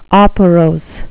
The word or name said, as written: operose